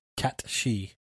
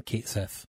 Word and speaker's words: Cait Sith: which I am going with the true Gaelic pronunciation rather than Kate Sith. – Yeah, like it's spelled Kate Sith. C-A-I-T-S-I-T-H. – Yes, but this is actually a creature of Scottish legend called the Cat She.